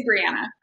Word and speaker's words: Breanna: It's Breanna.